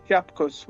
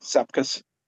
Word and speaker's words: Sepkus: Yeah, Lithuanians pronounce Sepkus with a SH sound, but Sepkus. But everyone in America calls it Sepkus.